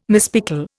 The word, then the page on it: mispickel: Indian